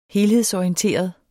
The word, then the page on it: helhedsorienteret: -ɒiənˌteˀʌð